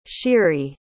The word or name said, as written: sheary